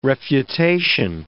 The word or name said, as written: refutation